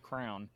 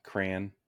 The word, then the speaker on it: crayon: They also like to pronounce it crown. And so when I say crown, it drives them nuts because it, it's almost like I'm putting too much emphasis on the word and making it more syllables than it needs to be.